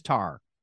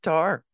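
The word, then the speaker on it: Tár: There's an accent on the A. Is it Tur? Is it just Tar? – It's just Tar.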